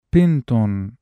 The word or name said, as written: بِنْتٌ (بَنَاتٌ)